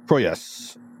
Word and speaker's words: Proyas: The director was Alex Proyas. I hope I'm pronouncing that correct.